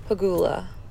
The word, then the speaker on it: Pegula: Hi, my name is Jessica Pegula.